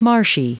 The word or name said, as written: marshy